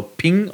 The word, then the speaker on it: PNG: Bei den Bildformaten, du hast vorhin PNG schon genannt oder Ping ausgesprochen offiziell. Da haben sie ausgeschrieben heißt das Ding ja Portable Network Graphics.